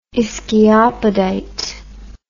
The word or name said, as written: ischiopodite